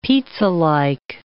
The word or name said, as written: pizzalike